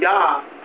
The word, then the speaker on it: Yah: It's spelled J-A-H, it's spelled Jah, but because in the Hebrew there is no J, it is spelled Yah. Okay, Y, you pronounce it with Y.